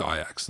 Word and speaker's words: Ajax: It's pronounced Ajax though, the proper Dutch way.